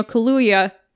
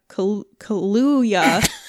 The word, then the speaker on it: Kaluuya: Daniel Kaluuya. Kaluuya. From Get Out. – You got it right the first time.